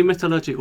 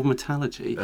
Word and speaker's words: metallurgy: Well, you can say metallurgy or metallurgy. All of that is correct.